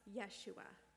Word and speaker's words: Yeshua: Joshua's name in Hebrew is pronounced Yeshua, which means the Lord is salvation.